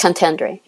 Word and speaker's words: contendere: So eventually he pleads nolo contende, contender, nolo contendo. How's that pronounced? He pleads contendere.